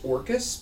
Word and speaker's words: Orcus: Symbol of Dorcas. – It's pronounced Orcus, but yeah, the D is silent.